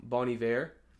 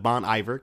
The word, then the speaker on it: Bon Iver: The fact that you say it like that, which is how he's pronounced instead of Bon Iver, because I know that's how most people say it.